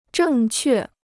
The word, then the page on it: 正确: zhèng què